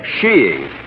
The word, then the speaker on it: skiing: skiing. – A little what? – Sheeing. Oh, there are other pronunciations, I suppose, but in England and Norway, sheeing is considered most acceptable.